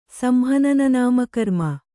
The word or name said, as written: ಸಂಹನನನಾಮಕರ್ಮ